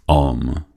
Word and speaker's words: Om: Aunque Om es considerada la sílaba sagrada, Om son tres sílabas, tres sonidos, tres fonemas. A, el principio, el planteamiento, la creación. Divinizado en Brahma. U, la conservación, el nudo, el desarrollo. Divinizado en Vishnu.